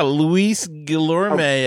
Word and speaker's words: Guillorme: Who is this Lewis Gilmore. Have you heard this one? He's on the 40 man roster. – Yeah, Luis Guillorme, oh.